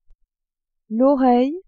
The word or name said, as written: oreille